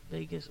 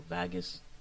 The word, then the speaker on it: vagus: Not vagus as in V-E-G-A-S, but vagus or vagus, probably the better pronunciation, V-A-G-U-S nerve, which is connected to our rest, our digestion, and our nervous system.